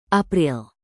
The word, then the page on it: april: indonesian